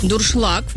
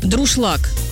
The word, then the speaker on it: дуршлаг: Юля написала нам, что ее бесит, когда говорят неправильно слово «дуршлаг». «Дуршлаг», правильно? Многие произносят как коверка «друшлаг».